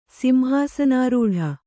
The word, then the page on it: ಸಿಂಹಾಸನಾರೂಢೆ: Kannada